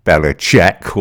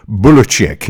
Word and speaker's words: Belichick: It's available via Chassis Media also appearing Ian O'Connor's book on Bill Belichick or Belichick, or it could even be Bulichick if you listen to the man himself actually pronounce his own name.